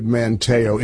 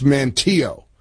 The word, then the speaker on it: Manteo: Incidentally, I knew I was mispronouncing that. As soon as I said Manteo, it's Manteo.